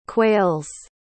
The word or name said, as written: quails